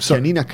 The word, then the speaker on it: Chianina: Yeah, we have chianina cows. – The typical. Pronounce that again for me. – Chianina.